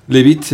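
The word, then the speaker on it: Leavitt: Sí, en efecto, dice la vocera de la Casa Blanca, Christine Levitt, que dice que sí es en efecto, se pronuncia así, Levitt, nos están comentando, así un abrazo además a quienes nos comentan, que es un muy buen amigo.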